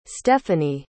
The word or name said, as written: stephany